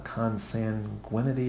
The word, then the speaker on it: consanguinity: So, yeah, there are certainly rules in Scripture about consanguinity. Is that what that's called? Did I pronounce that right? – Yeah.